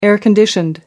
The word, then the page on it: air-conditioned: American English